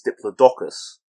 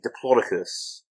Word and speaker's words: Diplodocus: Apparently, the guy at the lead scientist at the Natural History Museum, he said there's no one way to pronounce it properly, but he prefers Diplodocus. – Mm. – And I've heard people say Diplodocus as well, but that's too Roman for me. That sounds like a Roman general rather than a dinosaur.